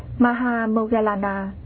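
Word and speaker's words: Moggallāna: Ngài Maha Mục Kiền Liên, Ấn Độ đọc là Maha Moggallana, chữ Hán là Đại Thái Thúc Thị, là bậc có phép thần thông đệ nhất.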